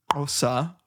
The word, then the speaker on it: Xhosa: Xhosa, and actually it's pronounced Xhosa, right? – Nice work.